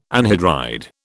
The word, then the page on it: anhydride: British English